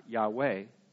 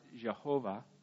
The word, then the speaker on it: Yahweh: So the word, it's interesting because it's the one you see, YHWH, and it's, and we pronounce it Yahweh. Some people pronounce it Jehovah, but no one's really sure because they didn't write down vowels. They only wrote down the consonants, and no one's been pronouncing the word for 3,000 years. So it's, we know the consonants, but you could pronounce those several different ways.